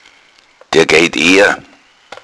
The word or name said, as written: eergehen